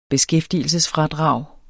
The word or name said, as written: beskæftigelsesfradrag